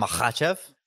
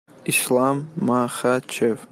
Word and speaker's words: Makhachev: I can't pronounce Islam Makhachev.